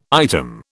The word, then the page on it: item: British English